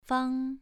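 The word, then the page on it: fang: tone 2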